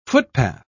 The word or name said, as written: footpath